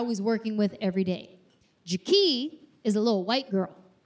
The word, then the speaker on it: Jaki: Her name is Jaki. Not Jackie. Jaki. It's spelled J-A-KEE.